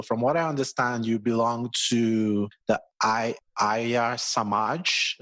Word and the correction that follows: Arya Samaj: if I pronounce that correctly. And you were raised in this religion did I get the pronunciation? The Arya Samaj? – Yes, it's Arya Samaj.